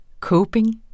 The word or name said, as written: coping